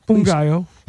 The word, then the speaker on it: Ngaio: My name's Ungayo Belem, by the way. No, go ahead. – Say your name again. – Ungayo.